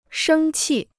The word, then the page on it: 生气: shēng qì